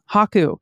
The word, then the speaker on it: Haku: I think Haku. Haku. Emphasize on the first syllable.